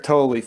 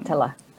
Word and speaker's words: Tolle: Tolle or Toll? – It's actually neither, but we Toll. I can't do the true Tolle or something. – Tolle is better. Yeah, something like Tolle.